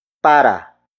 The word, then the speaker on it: para: The next word is para. Para. Para. Para is spelled as P-A-R-A.